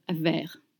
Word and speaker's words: vert: You don't pronounce the T at the end of vert.